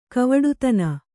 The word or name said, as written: ಗವುಡತನ